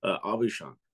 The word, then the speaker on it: Aubuchon: How do you pronounce, I just want to make sure that people know, how do you pronounce your last name? – Aubuchon.